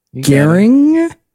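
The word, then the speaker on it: Goehring: Gehring.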